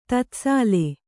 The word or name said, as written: ತತ್ಸಾಲ್